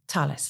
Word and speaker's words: Thales: okay, so you're working with Thales is how, yep. is how we pronounce it, isn't it? Because it's spelt T H A L E S. French. – So, ah, Thales. Ah, en français.